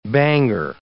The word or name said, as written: banger